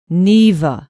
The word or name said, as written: neva